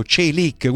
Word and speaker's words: Çelik: I primi due sono stati Ekator e poi Çelik. Ti ricordi, sì, la pronuncia l'ha detto il nostro Çelik, amico e giornalista turco, Çelik, quindi non sbagliate quando dovete chiamare.